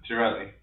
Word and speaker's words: Girelli: Girelli, yeah.